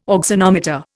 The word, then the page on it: auxanometer: Indian